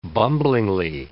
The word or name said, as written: bumblingly